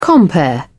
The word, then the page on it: compere: British English